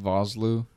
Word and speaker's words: Vosloo: Arnold Vosloo.